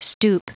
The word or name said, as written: stoop